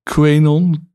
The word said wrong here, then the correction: QAnon: QAnon. QAnon. QAnon heet het, ja.